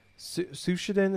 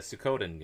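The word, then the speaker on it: Suikoden: They basically are Sushiden. Is that how you say it? Is that was it the games? – So. Sushiden? Maybe? – Sushiden, something like that. The old JRPGs. – That series. You mean the Sukkoden games, yeah.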